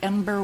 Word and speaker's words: Embr: Ember. Am I pronouncing it right? – Yes, EMBR, the Ember Wave.